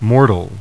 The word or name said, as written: mortal